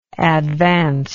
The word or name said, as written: advancingly